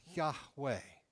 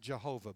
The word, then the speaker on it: Yahweh: out of the burning bush, God declares God's name, which in the ancient Hebrew would be pronounced Yahweh. Yahweh, think of it like a breath, Yahweh. Usually misspelled and mispronounced Jehovah, by the way, but that's another story for another time.